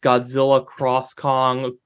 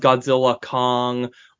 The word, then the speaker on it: Godzilla x Kong: Because so that is like, depending on the context, that is either pronounced Godzilla Cross Kong or just Godzilla Kong.